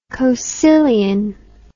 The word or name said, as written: coecilian